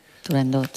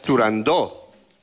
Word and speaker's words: Turandot: Turandot. – Turandó. – Turandot. – No. – Turandó. – No. No se dice, no se debe pronunciar Turandot. – Ah, no, Turandó. – La ópera se titula Turandó.